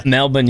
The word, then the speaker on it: Melbourne: Rams, Niners tonight in Melbourne. Is that that's how they say it? Melbourne, Australia. – Melbourne, yeah.